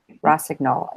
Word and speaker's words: Rossignol: and an elite cross country skier with Team Rossignol. Did I say that right? – You did.